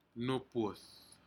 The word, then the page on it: nupud: Aneityum